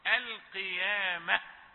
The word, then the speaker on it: القيامة: لابد أن يكون هناك هواء زفير يكفي للنطق بالهاء الموقوف عليه. القيامة اللوامة هكذا بدون افتعال، لا يضعف صوت الحرف الموقوف عليه ولا تبالغ فيه.